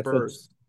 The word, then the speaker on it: Burse: I remember her correcting me about her last name, and then one of them not knowing that they rhymes were with Hearst too. Rhymes with Hearst. – That's what, yeah, Amanda Burse.